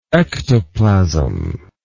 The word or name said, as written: ectoplasmatic